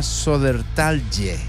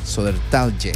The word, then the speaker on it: Södertälje: Quiero tratar de pronunciarlo bien, porque ya auxiliándonos de una herramienta, la ciudad se llama Södertälje. Södertälje.